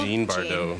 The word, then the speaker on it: Jean Bardot: June Bardot. – Jean Bardot. Jean. – Like Jean as in Jean Simmons, spelt with a J? – Yeah, exactly. Like Jean Harlow.